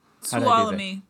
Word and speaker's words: Tuolumne: Tuolumne. – Tuolumne.